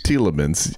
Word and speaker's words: Tielemans: It's Yuri Tielemans. Is that correct? – It's Tielemans. Yes. – Tielemans. Yes. Okay. Gotcha.